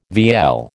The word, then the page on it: vielle: American English